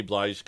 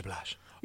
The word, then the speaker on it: Blige: Blige got... – You said that wrong. – What? – It's Mary J. Blige.